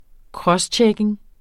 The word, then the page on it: crosschecking: ˈkʁʌsˌtjεkeŋ; ˈkɹʌs-